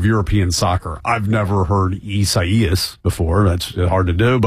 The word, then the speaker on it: Isaias: It's four syllables, apparently. I do believe that we should have a congressional a bill to pass that says any hurricane that we name should be easily pronounced. I mean, Isaias. I've honestly never heard of that name before. It's apparently Portuguese.